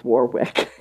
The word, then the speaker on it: Warwick: In Maine and Massachusetts and any of New England and England itself, the name W-A-R-W-I-C-K is not pronounced Warwick. It is pronounced Warwick, as in W-A-R-R-I-K or I-C-K.